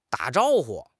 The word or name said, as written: da3 zhao1 hu5